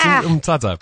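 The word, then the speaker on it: Mthatha: So they're taking them at the minute to the more underprivileged communities in Nelspruit, and please forgive my pronunciation of this, but Mtata in Eastern Cape. – You did pretty well. That's Mtata.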